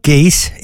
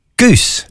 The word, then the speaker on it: Goese: Actually, the band on Saturday night is not Geese, it's Goose. Sorry, it's just spelled G-O-E-S-E, but pronounced Goose.